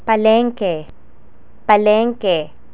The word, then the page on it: palengke: Tagalog